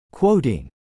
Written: quoting

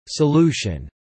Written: solution

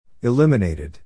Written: eliminated

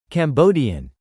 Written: Cambodian

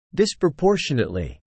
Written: disproportionately

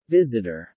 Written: visitor

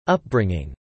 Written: upbringing

